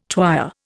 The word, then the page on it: twyer: Indian